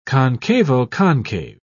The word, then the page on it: concavo-concave: British English